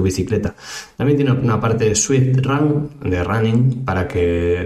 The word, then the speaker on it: Zwift: Si esto de la pronunciación no te queda muy claro, te lo deletreo: Z-W-I-F-T, Swift o Swift.